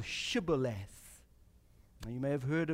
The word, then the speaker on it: Shibboleth: The Ephraimites cannot pronounce Shibboleth. So the Gileadites come up with this test for identification, and as an Ephraimite is coming from the battle, they say to him, "Are you an Ephraimite?" And when he says no, they say to him, "Well then say Shibboleth." And he says, "Sibboleth." Can't pronounce it.